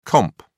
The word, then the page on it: comp: British English